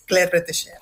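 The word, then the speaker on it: Claire Bretécher: Que nos dejó hace 6 añitos, en 2020, y menos mal que lo pronuncias tú bien, porque llevamos intentando pronunciarlo en la redacción nosotros todo el día y somos incapaces de pronunciar bien lo de Claire Retesher, pero bueno, ya está para decirlo bien.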